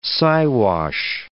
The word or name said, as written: Siwash